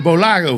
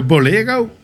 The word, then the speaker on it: Bolaro: Daar komt de afkorting Bolaro vandaan. Nou, dus daarom is het altijd heel raar om te horen dat het wordt uitgesproken als Bolero.